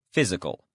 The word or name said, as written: physical